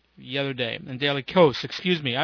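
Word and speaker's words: Kos: I've been calling it Daily Chaos because I thought that's what it was, but apparently the guy's name is Marcos, and he says, no, it's not pronounced chaos, it's pronounced coast.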